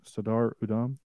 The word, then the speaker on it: Sardar Udham: There was a a lot of them said you need to do this movie called Sardar Udham. Did I pronounce that right? Sardar Udham. – Close enough.